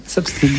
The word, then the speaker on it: soft skill: On va parler du soft skill, si je prononce bien. – Oui, soft skill.